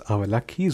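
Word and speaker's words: Avalakki: Poha oder auf meine Muttersprache heißt es Avalaki, so ist es ausgesprochen.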